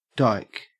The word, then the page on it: dike: Australian English